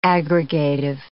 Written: aggregative

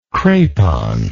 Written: crepon

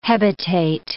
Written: hebetate

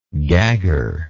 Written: gagger

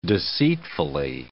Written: deceitfully

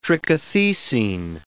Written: trichothecene

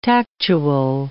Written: tactual

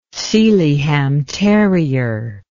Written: Sealyham terrier

